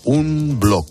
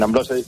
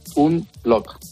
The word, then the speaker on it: Unblock: Oye, lo he leído tal cual se escribe, ahora me corriges. Un blog con CK. ¿Se dice así? – Exactamente. Vale. Sí, bueno, en unblock en inglés se diría unblock, pero deletreado en español, un blog.